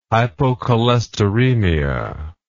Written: hypocholesteremia

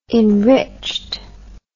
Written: enriched

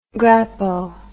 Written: grappo

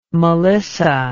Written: melissa

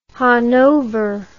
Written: Hannover

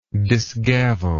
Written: disgavel

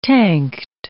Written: tanked

